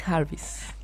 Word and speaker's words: Jarvis: ¿Ravis? – Anna Reeves Jarvis.